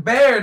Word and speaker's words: Bexar: It's Bear, not Bexar. It's the X is silent in Bexar, alright?